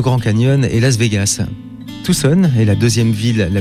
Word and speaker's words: Tucson: Nous sommes dans cette nouvelle émission pour la seconde fois à Tucson, Arizona, avec quelques petits problèmes de prononciation. Quelle est la bonne ? – Tucson.